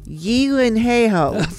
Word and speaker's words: Gyllenhaal: Is the actual Swedish pronunciation of Jake and Maggie Gyllenhaal. – Yeah. – Okay. It's their name, which is Yilenheho.